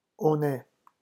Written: on est

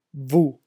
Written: vous

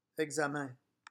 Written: examen

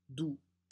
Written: doux

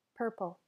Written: purple

